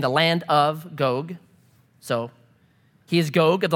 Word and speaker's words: Gog: A lot of people pronounce this Gog. It's actually Gog. It, it rhymes with Vogue, so it's Gog.